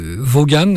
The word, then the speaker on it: Vaughan: Alors, ça s'écrit Vaughan, mais ça se prononce Sarah Vaughan.